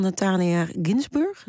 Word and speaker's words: Natalia Ginzburg: Je koos voor De Kleine Deugden van Natalia Ginzburg.